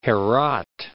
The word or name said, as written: Herat